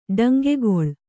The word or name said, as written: ಡಂಗೆಗೊಳ್